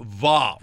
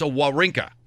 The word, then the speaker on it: Wawrinka: See, a lot of people see W-A-W and they think Va, right? It's W. It's a Wawrinka.